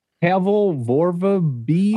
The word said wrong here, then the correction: Pavel Vorobiev: Oh, Pavel Vorobiev. Oh, he was. – Yeah, there you go. – He was this Russian. I butchered that one.